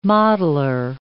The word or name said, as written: modeler